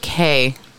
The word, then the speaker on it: Quay: I think they pronounce it K. – Oh, Quay. – It's a K or Quay? – It's pronounced K because it's an Australian company.